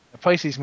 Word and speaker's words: Pisces: First email, I believe this is pronounced Pisces Moon? – Yeah. – Yeah. – Pisces Moon, Proud Moor Warlock.